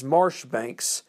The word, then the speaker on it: Marjoribanks: With the British on the run, only one of their units maintained ground, being Major John Marjoribanks, but the actual pronunciation is Marshbanks, Light Infantry and Grenadiers.